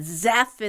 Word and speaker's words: Zeph: Zeph is a cool, you know, it kind of rhymes with Jeff.